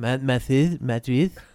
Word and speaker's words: Madrid: Madrid. Big up to the proper pronunciation on that.